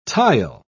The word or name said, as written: tile